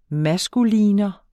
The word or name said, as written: maskulinum